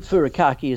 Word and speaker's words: furikake: So let's start off with the first one, furikake. I think I'm pronouncing it correctly. – Yes, I think furikake is correct. Our Japanese friends might correct us, but we'll stick with that.